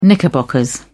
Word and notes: knickerbockers: British English

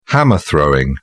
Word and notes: hammer throwing: British English

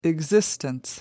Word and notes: existence: American English

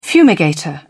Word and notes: fumigator: British English

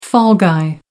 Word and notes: fall-guy: American English

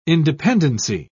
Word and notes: independency: British English

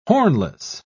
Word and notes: hornless: British English

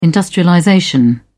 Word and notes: industrialization: British English

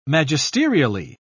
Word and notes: magisterially: British English

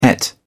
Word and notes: ate: British English

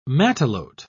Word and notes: matelote: British English

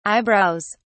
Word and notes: eyebrows: British English

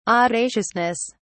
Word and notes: audaciousness: British English

